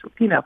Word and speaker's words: Rapinoe: And Megan Rapinoe, it's actually pronounced Rapinoe, talked, you know, I mean, she's fantastic.